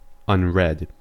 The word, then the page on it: unread: American English